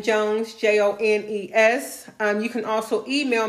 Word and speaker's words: Jones: So my personal, information, my Facebook is Jenny Jones.